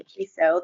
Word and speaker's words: Kiesow: My name is Stephanie Kieso. That's spelled K-I-E-S-O-W.